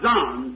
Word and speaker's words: zoon: Z-O-O-N? Living creatures. It might be pronounced Zon, Z-O-O-N. – How would you pronounce that? Zoon? – Zoon, I'd say. And Theron is T-H-E-R-O-N, Theron, see?